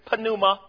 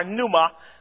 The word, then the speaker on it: pneuma: The word ghost is an English term that does not correspond with the Greek phrase pneuma or pneuma. Sometimes the P is silent.